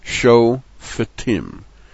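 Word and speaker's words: show fetim (שופטים): The fe with the shva under it is it's a shva na, and it does not close the first syllable. Instead, it begins the second syllable, fetim, show fetim, followed by the show terim, not shot rim. Shoterim. Shofetim are judges.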